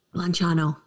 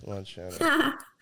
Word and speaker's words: Lanciano: Lanciano. – Yes, Lanciano.